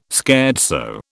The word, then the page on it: scherzo: British English